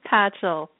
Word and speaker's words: Patchell: It's Patchell. – My husband always describes it as like Patchellini without the I.